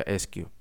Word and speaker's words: SKU: Así que puede que te estés planteando que qué es el SKU o por su sigla, las siglas son SKU, aunque se pronuncia SQ.